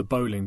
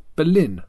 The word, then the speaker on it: Boleyn: I never know why it's pronounced the Bowling Ground, like tenpin bowling, rather than Boleyn, like Anne Boleyn.